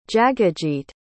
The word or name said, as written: jagajeet